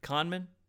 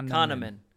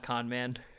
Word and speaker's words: Kahneman: How do you pronounce the author's name again? – Kahneman. – Kahneman. – Yeah. – Not con man.